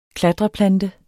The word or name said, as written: klatreplante